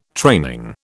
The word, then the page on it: training: British English